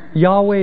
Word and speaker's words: Yahweh: Yahweh, Jehovah. Yahweh is the Hebrew pronunciation, Jehovah the anglicized.